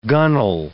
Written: gunnel

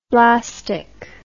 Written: blastic

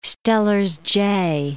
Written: Steller's jay

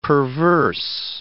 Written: perverse

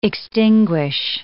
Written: extinguish

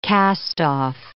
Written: cast-off

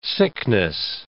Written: sickness

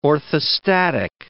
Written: orthostatic

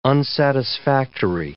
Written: unsatisfactory

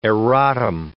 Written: erratum

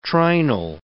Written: trinal

